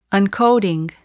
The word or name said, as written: uncoating